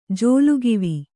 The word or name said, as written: ಜೋಲುಗಿವಿ